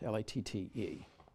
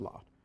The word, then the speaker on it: Latte: L A T T E. Latte, but I think it's pronounced Lot.